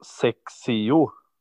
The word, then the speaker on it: XXIO: Men har du hört talas om märket som tydligen ska uttalas Sexio? Sexio, kanske. Sexio. – Nej, det har jag inte. – Det stavas XXIO.